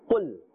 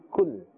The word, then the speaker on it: qul: Qul is with a qaf, and qaf is part of the huruf musta'aliya, therefore it must be pronounced with a thick tone. Do not confuse this with the kaf. If we pronounce with a kaf, it will be kul. Kul. Kul is very gentle. Kaf has a gentle sound. And qul has a harder sound with a full mouth.